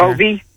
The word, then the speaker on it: Hovey: We have been pronouncing his name wrong. – Okay. – It is Major Hovey. – Yes, like Hovey.